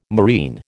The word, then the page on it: marine: American English